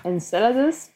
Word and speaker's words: Enceladus: that is Enceladus. Is that how you pronounce it? How do we... – Enceladus, but I... – Enceladus.